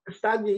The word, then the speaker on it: Castagne: Left-back, they got that Castagne in it from Leicester, if that's how you pronounce it. – Castagne, yeah, exactly.